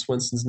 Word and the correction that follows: Swanson: name? – You said no. Swanson.